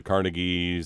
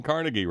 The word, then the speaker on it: Carnegie: You're not saying Carnegie right. No, that's how Andrew actually pronounced his name.